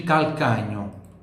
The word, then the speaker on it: calcagno: Il calcagno. Il calcagno. Il calcagno. Atenção para o som do L no il e no cal. Ó. Calcagno. Calcagno. Calcagno. Calcagno. Il calcagno. E GN tem som de NH.